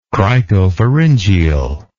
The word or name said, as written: cricopharyngeal